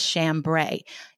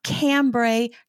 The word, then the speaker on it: chambray: Today, we are talking about my favorite, hands down favorite summer fabric, and that is chambray. It is spelled C-H-A-M-B-R-A-Y, and it is pronounced chambray, not cambray, not chambray, not chambray, chambray.